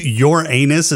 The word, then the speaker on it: Uranus: Since Uranus is a gas giant and it still exists. – Uranus. Say it right. – Okay, sorry. Since your anus is a gas giant.